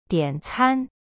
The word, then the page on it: 点餐: dian can